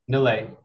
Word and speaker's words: Nilay: Nilay Kantaria. – Spell that, please. – N-I-L-A-Y.